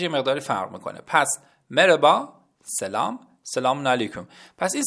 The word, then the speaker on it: مرحبا: مرحبا به معنی سلام هست. اما بچه ها اینو بهتون بگم، توی تلفظ عامیانه مرحبا نمیگن، نوشته میشه اما مربا خونده میشه یا به نوعی مربا گفته میشه.